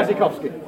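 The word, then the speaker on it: Błaszczykowski: Blachikowski. – Drafts out to the left. I don't know if that's how you pronounce it. I guess. – Yeah, it is. – I'm just listening. Yeah. Blachikowski.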